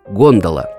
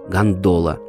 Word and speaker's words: гондола: Действительно, венецианцы говорят «гондола», но в русской традиции устоялось ударение «гондола» на вторую «о».